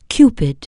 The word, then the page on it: cupid: anglais